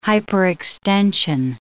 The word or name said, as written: hyperextension